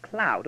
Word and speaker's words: Klau: Well, then, the name of the German Jesuit I have been speaking of is Christoph Klau, pronounced Klau. Don't you see the pun? Klau, clue?